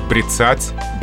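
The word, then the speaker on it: бряцать: И правильно говорить «брицать», «брицание». Ударение здесь падает на «а».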